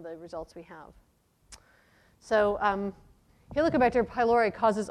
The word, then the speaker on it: Helicobacter: This is a very common pronunciation problem. It's Helicobacter.